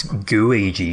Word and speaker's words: Gooigi: Is that with the very cleverly named Gooluigi or Gooigi or how are we pronouncing this? What's the correct terminology? – I think it's Gooigi because he's, a gooey Luigi.